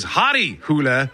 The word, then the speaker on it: Harry Hole: starring a character whose name is Hadi Hule, or as it's spelled, if you were to read it in English, his name is Harry Hole.